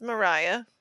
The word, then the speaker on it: Moriah: Dude, it's spelled M-O-R-I-A-H. – I know. I keep calling How do you announce that? – Like Moira, enunciate but that. It's Mariah.